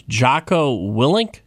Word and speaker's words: Jocko Willink: His name is Jocko Willink. Am I saying that correctly? – Yes.